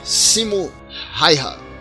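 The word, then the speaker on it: Simo Häyhä: Eu queria falar aqui então sobre Simo Raiha. Dario, minha pronúncia do finlandês foi adequada? Foi? – Sim. – O A com trema no novo acordo ortográfico finlandês pronuncia. Correto.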